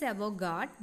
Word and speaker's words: avant-garde: The spelling, sorry, the pronunciation is avant-garde, but it is written as A-V-A-N-T, avant-garde, G-A-R-D-E. That is the spelling, but it is pronounced as avant-garde.